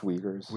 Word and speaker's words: Uyghurs: Mostly on the detainment of, I'm going to butcher how to pronounce this, but it's pronounced Uyghurs. – Uyghurs, okay.